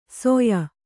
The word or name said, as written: ಸೊಯ